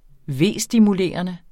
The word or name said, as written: vestimulerende